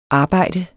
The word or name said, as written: arbejde